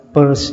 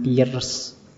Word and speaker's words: Peirce: Sanders Peirce Itu bacanya Peirce ya, jangan Pierce Ya, Kayak kalau kalian baca dompet P U R S E purse. Jadi le lebih seperti itu membacanya.